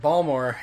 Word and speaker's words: Baltimore: for those listeners not from here, Ballmer is how people from Baltimore pronounce Baltimore.